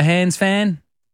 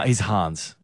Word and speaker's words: Hans: Mandy, are you a Hans fan? – Yes, I am. – He's Hans. He's German. – I was trying to rhyme with fan.